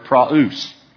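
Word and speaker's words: praus (πραΰς): It is the word praus.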